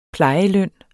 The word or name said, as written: plejeløn